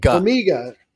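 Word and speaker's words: Ogbongbemiga: And, you know, one of those guys is Amon Ogbonbamija. Did I say that right? – Ogbonbamija. Bamiga. – Bamiga. Hard G, not soft G.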